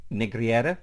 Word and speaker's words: Negreira: who is Jose Maria Enrique Negreira. Did I say that right, Jesse? – Yes, but look, here's the thing.